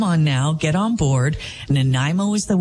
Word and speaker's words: Nanaimo: N-A-N-A-I-M-O, Nanaimo. Maybe it's just because I grew up here. I don't know. But some of the mispronunciations, Nanaimo, Nanaimo, Nanaimo, Nanaimo. I literally heard people saying these kinds of things.